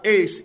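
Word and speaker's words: ace (εἰς): The word used here, which says believe in, the word in is pronounced ace, E-I-S is how we would spell it.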